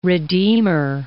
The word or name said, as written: redeemer